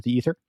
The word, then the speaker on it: aether: And this sometimes you'll see it spelled E-T-H-E-R, sometimes A-E-T-H-E-R, and that's because it's transliterated from the Greek, but it's pronounced ether, either way.